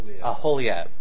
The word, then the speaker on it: Aholiab: Bezalel, I'm going to mispronounce the name. Oliab, was it the Oliab, the second in charge of the artisans? So he started to bring, say again? – Aholiab.